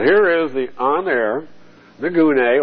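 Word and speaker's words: gune (γυνή): The word for woman in Greek is gune. We'll use the Hebrew word, isha. That's nicer, isha. See, rather than gune, because we're so geared to goon.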